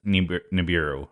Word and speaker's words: Nibiru: Nibiru, is that how you say it? – I believe it's Nibiru.